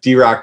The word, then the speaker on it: Derock: What's your, I know that your name is spelled Derek, like normally Derek, but you went Dare Rock on your Instagram, correct? – Yeah. So, so you can find me on Instagram at DRock Tattoos.